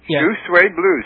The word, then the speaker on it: Shoe Suede Blues: I mean, And it's two suede blues. – No, shoes. It's like a blue suede shoes. – Hang on. Shoe Suede Blues. He said it right.